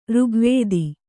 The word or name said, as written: ಋಗ್ವೇದ